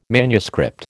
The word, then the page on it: manuscript: American English